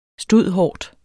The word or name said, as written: stud.hort.